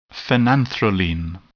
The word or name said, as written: phenanthroline